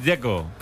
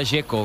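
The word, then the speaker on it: Džeko: Es Dzeko. – Como tirando a J, Dzeko o algo así.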